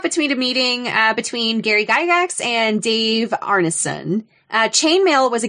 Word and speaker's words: Gygax: came out of a meeting between Gary Gygax.